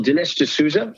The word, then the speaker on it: Dinesh D'Souza: Well, Dinesh D'Souza, is that what you mean? – Yes.